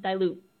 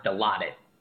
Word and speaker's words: Dilaudid: has failed another drug test of marijuana and dilute. – I think it's pronounced dilaudid. I think. – Whatever. It looks like dilute.